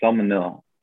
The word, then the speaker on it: semolina: Pasta is processed from germ, and I'm, you know, this pronunciation isn't gonna be good, but noodle or pasta is processed from durum salmon. I think it's semolina.